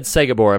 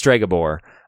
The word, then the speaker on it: Stregobor: I said Sogabor. I've been saying it wrong this whole time. It's Stregobor.